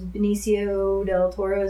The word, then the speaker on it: Benicio del Toro: And it was the year that the Wolfman was Benicio del Toro, is that how you say it? – Mm-hmm.